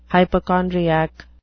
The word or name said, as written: hypochondriac